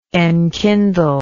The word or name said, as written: enkindler